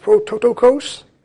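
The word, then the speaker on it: prototokos (πρωτότοκος): The word, I think I'm going to butcher this, but it's pronounced prototokos.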